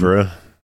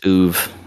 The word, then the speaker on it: oeuvre: I think it's oeuvre. – It's either that or just oov.